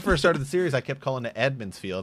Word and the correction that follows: Emond's Field: and the people were like, "It's Emond's Field."